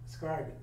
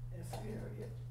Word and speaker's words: Iscariot: Mm-hmm. Iscariot. – Iscariot. – And what is it? – Iscariot. – Oh, Iscariot, okay. Yes. Simon, the Canaanite, and Judas Iscariot, who also betrayed him.